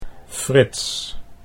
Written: Frits

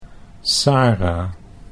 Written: Sara

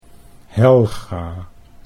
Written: Helga

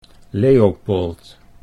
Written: Leopold

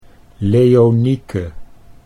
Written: Leonieke

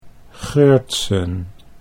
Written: Geurtsen

